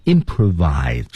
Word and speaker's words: improvise: 就是大家经常说的即兴表演即席表演 improvise 注意发音唇齿的配合